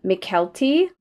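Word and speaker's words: Mykelti: Tell me if I'm pronouncing this name wrong. McKelty Williamson? – I think that's fine.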